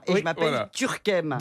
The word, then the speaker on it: Turckheim: Moi, ça fait des années que vous me dites que je m'appelle De Turckheim. Ça fait des années que je vous dis qu'on prononce pas la particule.